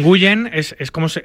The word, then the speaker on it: Nguyen: Se pronuncia W-I-N, pero se pronuncia Win. Sí. Por ejemplo, Scotty Nguyen, que ha sido campeón del mundo, que es el Nguyen más famoso que hay, sí, es Scotty Win, porque se pronuncia Win. Pero se escribe así, N-G-U. Y-E-N, Nguyen.